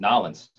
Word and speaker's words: New Orleans: It's like if you live down in New Orleans, it's Nolens.